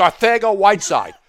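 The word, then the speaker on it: Arcega-Whiteside: Arcega Whiteside, even though it's spelled Arcega? It's Arcega Whiteside.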